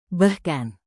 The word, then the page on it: bahkan: indonesian